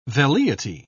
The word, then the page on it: velleity: British English